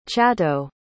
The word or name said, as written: chato